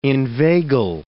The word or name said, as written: inveigle